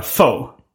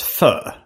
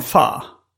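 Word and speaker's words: Phở: Min storebror sa att det ska uttas fö. – Mm. – I Doughboys-podden sa de fa. – Mm, oh gud. – och jag kollade på YouTube idag. Där det fanns liksom en instruktion i hur man uttalade korrekt vietnamesiskt. – Mm. – Och då var det att man skulle börja med ett ö-ljud. Glida över till ett A och öet skulle vara lite tystare och sen så avsluta med någonting där man skjuter fram käken.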